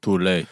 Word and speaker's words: Thule: Thule.